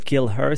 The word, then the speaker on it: Keeleher: Juste avant Kill Her, c'était Kill Her, en fait, qui s'écrit également Kill, K-E-E-L-E-H-E-R.